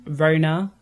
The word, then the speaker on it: Roena: I'm Rona, but spelled R-O-E-N-A. So phonetically to my name, that's not actually the spelling.